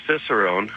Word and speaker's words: Cicerone: How did you become Alberta's first, am I pronouncing it right, a Cicerone? – It's just Cicerone.